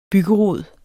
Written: byggerod

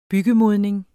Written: byggemodning

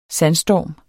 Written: sandstorm

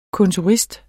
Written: kontorist

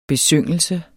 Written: besyngelse

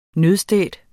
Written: nødstedt

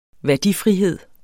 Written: værdifrihed

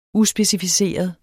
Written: uspecificeret

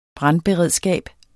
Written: brandberedskab

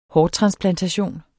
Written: hårtransplantation

